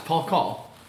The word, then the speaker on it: Paul Call: It rhymes. Paul Call. Paul Call.